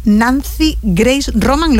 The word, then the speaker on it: Nancy Grace Roman: Del exitoso telescopio espacial Nancy Grace Roman, ¿lo digo bien? – Sí.